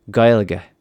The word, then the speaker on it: Gaeilge: And in the actual Gaelic, it sounds more like Gaeilge.